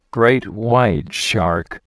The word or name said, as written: great white shark